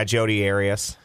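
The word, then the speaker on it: Jodi Arias: Yeah, Jody Arias.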